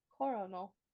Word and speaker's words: Coronal: Spell it. – C-O-R-O-N-A-L. – Coronal. – Yeah. Coronal Island.